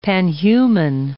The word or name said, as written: panhuman